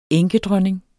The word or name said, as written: enkedronning